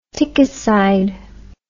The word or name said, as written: tickicide